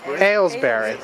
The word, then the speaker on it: Aylesbury: So, first of all, this is here in Aylesbury. Am I saying it right? – Aylesbury.